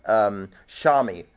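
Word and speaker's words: Shammai: The other camp was Shimel, I think it's pronounced Shami, short, Shami.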